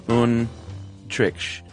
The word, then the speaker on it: Antriksh: And this is how his name is spelled, A-N-T-R-I-K-S-H, Yadav, Y-A-D-A-V. And he says, you pronounce it Untrich. The T is soft, though, as in math. So Untrich? – Yeah, there you go.